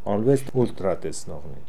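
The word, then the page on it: Olooessultratesnokhnee: Russian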